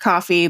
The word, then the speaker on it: Ko-fi: So after much thought, we have decided to launch a paid subscription powered by Ko-fi, pronounced coffee, but spelled K-O-F-I.